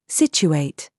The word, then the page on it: CHURCH: British English